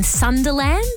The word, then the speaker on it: Sunderland: This year it's going to be held in Sunderland. Am I saying that right? – Yeah, as a Sunderland.